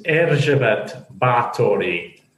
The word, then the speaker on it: Erzsébet Báthory: The Hungarian pronunciation was Erzsébet Báthory. So that's how she would have pronounced it and the people around her.